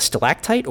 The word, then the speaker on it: stalactite: I think I've always pronounced it with a G, stalactite and stalagmite, but it is a C for stalactite.